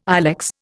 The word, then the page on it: ilex: Indian